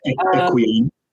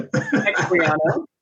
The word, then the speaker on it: Equiano: Am I pronouncing that correct? – Yeah. Equiano. – What? – Equiano. Yeah, there you go. – Equiano.